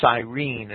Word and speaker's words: Cyrene: Many commentators have asserted that this Simon was some sort of brown Arab or even a Negro simply because he was from Cyrene or Cyrene, it's often pronounced. It's spelled C-Y-R-E-N-E, which was a district in Africa.